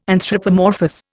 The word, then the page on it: anthropomorphous: Indian